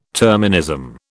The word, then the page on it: terminism: British English